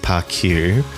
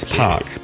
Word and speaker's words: ParQ: Well, I learned that Park Bar is definitely not pronounced ParQ. – Or ParQua. – ParQua. ParQ. It's Park. Park Bar.